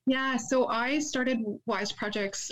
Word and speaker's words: YZED Projects: Yeah, so it's pronounced YZED Projects.